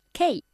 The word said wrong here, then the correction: key: ¿Cómo se pronuncia tecla? – Key. – Key, mucho mejor.